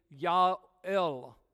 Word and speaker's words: Joel: Joel is really Ya'el. Joel, Ya'el is how you pronounce his name.